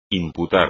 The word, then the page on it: imputar: espagnol